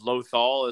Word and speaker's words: Lothal: I call it, because it's Lothal is how it's pronounced, so I always called it a Loth cat.